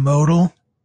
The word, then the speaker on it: Meauxdal: I am Modal, and that's spelled MEAUXDAL.